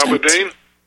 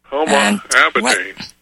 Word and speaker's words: Huma Abedin: Now, she has this individual, Huma, is that how you pronounce her name? Her assistant. – Huma Abedin? – Yeah, her assistant. – Huma Abedin.